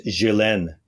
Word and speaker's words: Ghislaine: It is Ghislaine Maxwell. Is that right? Am I saying it right, Jen? Ghislaine? Yes? Ghislaine. Ghislaine. All right, whatever.